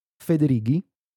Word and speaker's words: Federighi: All right, so the way you pronounce his last names in Italian is Federighi.